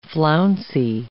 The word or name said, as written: flouncy